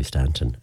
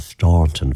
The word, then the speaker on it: Staunton: I have a feeling that that town may well have been named after somebody from County Mayo, because the traditional pronunciation of that name here in Mayo would be Stanton, a silent Jew, even though the more posh version of it would be the Staunton version of it, which a lot of RT folks and all of those like to use when they're talking about Cora.